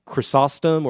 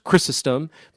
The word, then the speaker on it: Chrysostom: So yes, it's easy enough to find, you know, the Good Friday reflection by St. John Chrysostom, or Chrysostom, pronounced both ways, and it appears in the Office of Readings on Good Friday morning.